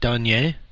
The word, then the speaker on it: Doyenne: Anyhow, how do you say it? Donay? – Doyenne. – Doyenne. – D-O-Y-E-N-N-E. – D-O-Y-E-N-N-E, correct. – Doyenne.